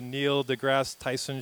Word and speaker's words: Neil deGrasse Tyson: It's like when you watch those science shows, like, you know, the Neil deGrasse Tyson shows.